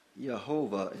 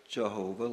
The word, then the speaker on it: Yehovah: You know, in this passage, the Lord, that's the capitalized L-O-R-D, means Jehovah, we often say in English. It's the Yahweh God, according to Strong's. It's pronounced more exactly Yehovah instead of Jehovah, like we tend to in English for the J.